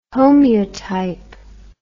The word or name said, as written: homeotype